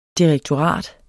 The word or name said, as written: direktorat